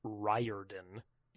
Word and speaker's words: Riordan: Apropos of nothing, I understand his last name is actually pronounced Riordan, and that it is not uncommon to call him Uncle Rick.